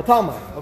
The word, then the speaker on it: Talmai: Ptolemy? Ptolemy, in Greek. Oh, Talmai. Oh, Talmai. Okay, Talmai. They just put a P there.